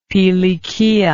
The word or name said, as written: pilikia